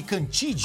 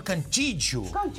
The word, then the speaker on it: Cantillo: O Victor Cantillo, ele é colombiano, a gente chama ele de Cantide. – Ah, eu já falei errado. – Não. Você falou em português. – Eu falo como tá escrito. – Mas em português, mas na Colômbia ele é chamado de Victor Cantillo.